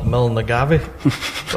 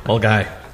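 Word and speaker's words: Milngavie: Mulgai's been mentioned a few times, and I've always pronounced it wrong, called it Milnagavi. But it's not that. I've been corrected many times by members of Mulgai, saying, that's wrong. It's Mulgai.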